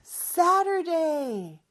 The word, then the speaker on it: Saturday: 자, Saturday 발음할 때는 저 T 발음에 water가 water가 되는 것처럼 이렇게 조금 혀가 입천장에 살짝 닫아떨어지는 리으라고 비슷한 발음이라고 보시면 돼요. It's Saturday.